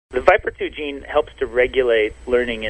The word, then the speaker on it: VIPR2: Scientists focused on the gene for VIPR2, pronounced Viper2, a receptor for one of these neuropeptide chemical messengers.